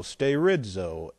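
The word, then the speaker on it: sterizo (στηρίζω): This makes it even more interesting, which is S-T, and then we have E-R-I-Z-O, sterizo, S-T-E-R-I-Z-O, long O on the end, sterizo.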